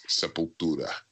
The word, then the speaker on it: Sepultura: But we make fun of it, you know, ourselves. We'll be like, Sepultura, hard, heavy. I love that man, you know, but I mean, those guys don't care. I mean, I don't care. So, but it's funny, but it's Sepultura.